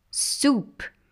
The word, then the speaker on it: soup: Soup. Nota que a pronúncia também muda. Soap, S-O-A-P, soup, S-O-U-P.